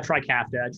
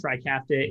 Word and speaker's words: Trikafta: So yeah, you're talking about Trikafta. Trikafta is a three-component drug, and essentially it corrects the protein dysfunction at the heart of cystic fibrosis.